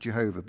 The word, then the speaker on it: Yahweh: And there's a discrepancy because scholars disagree on how the ancient Hebrew language is pronounced. So some think it's pronounced Yahweh and some think it's pronounced Jehovah, but the two are the same thing.